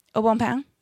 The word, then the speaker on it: Au Bon Pain: I'm sorry, how did you say that word? – Aubon Pain. – Oh, okay. You're using the proper French dialect to say this.